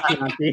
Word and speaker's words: Chianti: Oh, crack open a bottle of Chianti. There you go. I think I said it right. – Chianti. Pretty sure it's Chianti. Chianti.